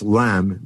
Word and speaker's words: ylem: Well, Gamow, and working with his student Ralph Alpher, he calls the initial substance of the universe LEM, which is spelled Y-L-E-M, which is an ancient Latin term for matter.